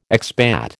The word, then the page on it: expat: American English